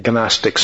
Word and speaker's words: Gnostics: I'm saying Gnostics, so you know what I'm talking about. It's Gnostics, but you can always pronounce the G if you don't know how to spell it, right?